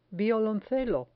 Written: violoncelo

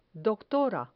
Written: doctora

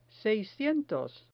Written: seiscientos

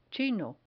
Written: chino

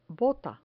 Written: bota

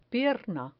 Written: pierna

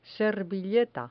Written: servilleta

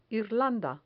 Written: irlanda

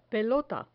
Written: pelota

